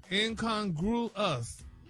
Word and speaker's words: incongruous: No, he had the last syllable wrong. He just now you got the right syllables, and it's broken down for you. – Yeah, break it down. – Incongruous. – There you go.